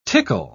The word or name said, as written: tickle